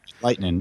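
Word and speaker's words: Lightnin': It's called Hunch Punch Lightning, and it's LIGHT. N-I-N apostrophe. That's how they say it down there. – Lightning. Okay. – Like lightning rod.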